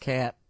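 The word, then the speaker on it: Katt: Don't know where he got William Cott from. – I'm not sure. – Pronounced Catt.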